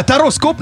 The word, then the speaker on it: Тароскоп: Ну, вообще-то, слышали, как правильно произносилась рубрика? Это вам не Таро-подшипник! Таро-подшипник, а Тароскоп на Радио Энерджи.